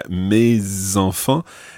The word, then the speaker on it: mes enfants: Mes enfants. It's called liaison. So the S of mes follows on and you pronounce it because it's followed by an E.